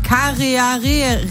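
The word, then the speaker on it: Kārearea: Ein flugunfähiger Papagei und den Kareharea. Das ist ein neuseeländischer Falke, der wird genauso ausgesprochen auf die Plätze.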